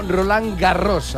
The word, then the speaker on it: Roland Garros: En francès, Roland Garros se pronuncia aproximadamente como Roland Garros. A diferencia de la mayoría de las palabras francesas donde la S final no suena, en este apellido sí se pronuncia la S del final de Garros.